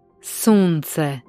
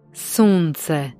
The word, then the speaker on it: sunce: Das S im Kroatischen wird als scharfes S ausgesprochen, wie im deutschen Wort Soße. Ein Beispiel hierfür ist Sunce, was Sonne bedeutet. – Sunce. – Sonne. – Sunce.